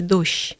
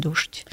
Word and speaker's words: дождь: Более старшая норма вот это смягчение двух согласных, которые подряд идут, да, мягкий дождь. Но можно и первую согласную твердо произносить дождь.